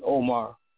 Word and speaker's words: Omar: My name is Shahid Omar, and it's spelled S-H-A-H-E-E-D. Last name is O-M-A-R.